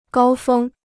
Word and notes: 高峰: gāo fēng

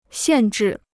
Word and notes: 限制: xiàn zhì

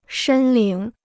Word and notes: 申领: shēn lǐng

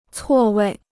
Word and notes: 错位: cuò wèi

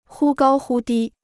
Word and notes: 忽高忽低: hū gāo hū dī

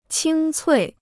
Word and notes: 清脆: qīng cuì

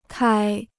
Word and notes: 开: kāi

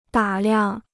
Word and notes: 打量: dǎ liang

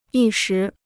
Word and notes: 一时: yī shí

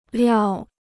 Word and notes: 料: liào

